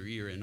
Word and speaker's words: Erie: As is held in the Erie, and they pronounce it locally Erie, in Your Honor, regardless of the spelling, in the Erie and Sterling decisions, which are factually and legally undistinguishable from what is here today.